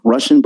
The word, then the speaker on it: RVSSIAN: And the last one for the night, it's called Eminem, and it's by a person by the name of Russian, but it's spelled RVSSIAN.